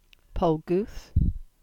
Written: Polgooth

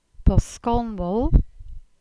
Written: Boskonwal

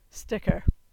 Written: Sticker

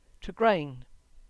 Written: Tregrehan

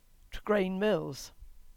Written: Tregrehan Mills

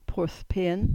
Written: Porthpean

